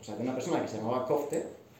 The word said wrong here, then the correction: Couth: bueno, Couth, la pronunciación es Couth, y que quería recopilar todas esas historias, quería hablar con la persona y quería recopilar todas las historias.